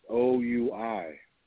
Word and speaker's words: Oui: That's how you pronounced O U I.